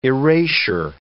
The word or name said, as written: erasure